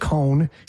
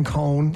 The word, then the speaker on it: Coen: He's just Cohn, C-O-E-N, Cohn. – Drives me crazy. – Like ice cream. It's just, so if you spell it C-O-N-E, people are like, oh, from an ice cream family.